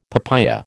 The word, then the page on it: papaya: American English